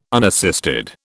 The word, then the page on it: unassisted: British English